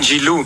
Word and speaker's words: Giroud: No, Giroud. No, G, no, not with the L, not Giroud, Giroud.